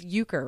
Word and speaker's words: Euchre: Kenneth said, card game, it's Euchre, right? That's how you pronounce it? – Yeah.